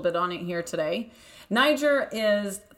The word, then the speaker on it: Nyjer: So now in the industry, we call it Niger, and we spell it N-Y-J-E-R.